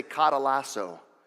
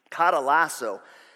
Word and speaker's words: katalasso: I had to actually look up how to say that. There's like a thing where you can sometimes put in the Greek and help you to pronounce it. Katalasso.